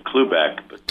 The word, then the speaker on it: Cloobeck: Did I say that right, Stephen? – You did. It's Cloobeck. – Cloobeck. Cloobeck. C-L-O-O-B-E-C-K. Stephen J. Got it. From here on out, I'm going to... S-J-C. Just S-J-C.